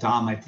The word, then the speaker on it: dhamma: It's pronounced dharma, D-H-A-R-M-A. There's a more common word that's similar from Buddhism called dhamma, D-H-A-M-M-A.